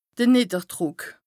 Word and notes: de Nìdderdrùck: Bas-Rhin; Strasbourg

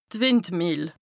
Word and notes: d' Wìndmìehl: Bas-Rhin; Strasbourg

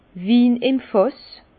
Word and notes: Win im Fàss: Alsatian; Bas-Rhin; Herrlisheim

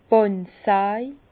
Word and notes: d' Bàndsaj: Bas-Rhin; Herrlisheim

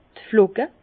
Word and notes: d' Flocke: Bas Rhin; Herrlisheim

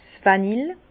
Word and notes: 's Vanille: Bas Rhin; Herrlisheim

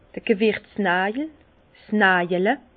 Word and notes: d'r Gewirtznàjel, 's Najale: Alsatian; Bas-Rhin; Herrlisheim

